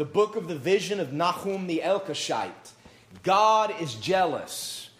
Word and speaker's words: Nahum: Obadiah, Jonah, Micah, Nahum is how it's pronounced in Hebrew. Nahum is just an English way of saying it, but it's Nahum. Every time I say Nahum in the presence of an Israeli, they correct me.